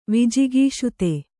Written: ವಿಜಿಗೀಷುತೆ

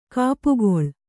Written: ಗುಪ್ಪೆಗೊಳ್

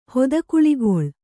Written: ಹೊದಕುಳಿಗೊಳ್

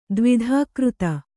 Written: ದ್ವಿಧಾಕೃತ

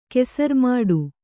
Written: ಕೆಸರ್ಮಾಡು

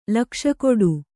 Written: ಲಕ್ಷಕೊಡು

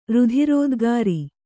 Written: ರುಧಿರೋದ್ಗಾರಿ

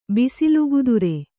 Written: ಬಿಸಿಲುಗುದುರೆ

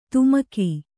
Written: ಧಿಮಿಕು

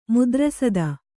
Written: ಮೂತ್ರಸಾದ

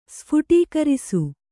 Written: ಸ್ಫುಟೀಕರಿಸು

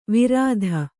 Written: ವಿರಾಧ

